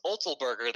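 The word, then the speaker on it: Otzelberger: So their head coach, I never pronounce his name right, Olsterson Olzelberger, there we go.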